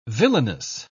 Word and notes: villainess: British English

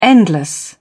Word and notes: endless: British English

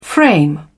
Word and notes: frame: British English

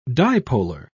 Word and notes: dipolar: British English

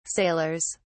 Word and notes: sailors: British English

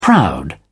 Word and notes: proud: British English

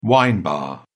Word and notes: wine bar: British English

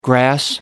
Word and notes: grass: American English

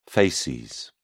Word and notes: facies: British English

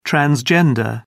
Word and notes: transgender: British English

